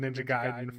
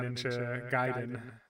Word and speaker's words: Ninja Gaiden: I'm going to try to stick with Ninja Gaiden for the future, for you curious. – I believe that is the canonical pronunciation.